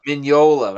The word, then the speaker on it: Mignola: I think it's a soft G. It's a Mignola. – Mignola, that's right.